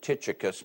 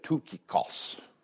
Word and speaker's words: Tychicus: All my life I pronounced it Tychicus because of the CH there, but it's really the Christ, the CHK sound.